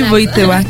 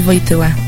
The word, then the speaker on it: Wojtyła: Sí, Wojtyła, Karol Wojtyła. – Ah, muy bien, ahí tienen la pronunciación exacta.